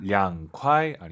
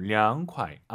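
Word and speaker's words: 凉快: 량콰이. – 선생님, 한국 분들은 초급자 분들 특히 중국자도 마찬가지지만 이성경성이 어려워요. – 아하. – 량콰이 아니고 량콰이. 아하. 이 발음 기억하세요.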